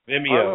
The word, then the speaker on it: Vimeo: Vimeo? Vimeo? I don't know. – Vimeo.